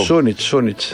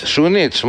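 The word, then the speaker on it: Šunjić: Σούνιτς, καλά τον πάω. – Σούνιτς. – Σούνιτς, μάλιστα, ναι. Χωρίς γάμα. – Χωρίς γάμα.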